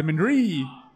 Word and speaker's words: Mindari: I wasn't sure where to put the emphasis. Beer de Mindari. Mindari. Yes.